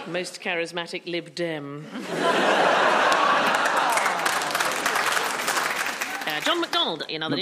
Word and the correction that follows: McDonnell: Not McDonald. – Oh, you come over here, you take McDonald, over our panel shows, you can't pronounce our names. – I'm so sorry. John McDonnell,